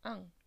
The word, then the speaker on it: en: Sabéis que en se pronuncia an, lo he dicho muchas veces.